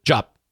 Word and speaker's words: Diop: Jop.